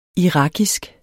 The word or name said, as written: irakisk